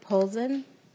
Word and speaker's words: Polzin: Up next is Jennifer Polzin, from the Executive Director of Tubman. And on deck is Rob Stewart. Welcome to the committee, Ms. Polzin.